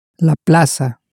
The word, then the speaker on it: la plaza: The plaza is spelt exactly the same way as in English, except the Z in Spanish more or less adheres to the Cecilia rule. The Cecilia rule, if you'll remember, tells us that a C in front of an E or I in Spanish is pronounced like an S in Latin America, but like a th, as in think, in most of Spain.